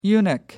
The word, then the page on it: eunuch: American English